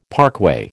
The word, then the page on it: parkway: American English